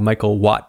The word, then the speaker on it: Michal: Today I have Michal.